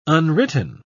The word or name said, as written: unwritten